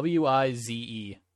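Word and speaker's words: Weis: So, it's from like the Wise grocery store? – No, it's like Wize. I've never heard of a Wise grocery store. – Yeah, there's a Wise grocery store? – Yeah. Yeah, it's spelled Weiss.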